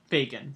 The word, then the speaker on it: Fagin: Fagin.